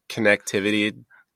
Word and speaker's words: connectivity: Because connectivity sounds weird. I don't know. – You always say that, and that is correct. So when I looked up the pronunciation, it is connectivity.